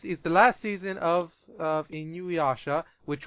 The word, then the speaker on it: Inuyasha: How do you pronounce that? Inuyasha? – Isn't it Inuyasha.